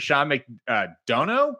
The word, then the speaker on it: Sean McDonough: Looks like the Chiefs had fought back the comeback, and the announcer, ESPN's announcer, Sean McDonough?